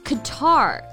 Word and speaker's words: Qatar: Qatar 中音呢在第二个音节。